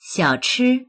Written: xiao3chi1

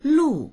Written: lu4

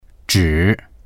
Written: zhi3

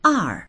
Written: er4

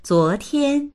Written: zuo2tian1

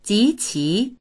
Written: ji2qi2

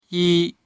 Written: yi1

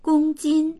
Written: gong1jin1

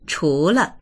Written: chu2le

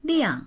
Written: liang4

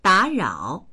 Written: da3rao3

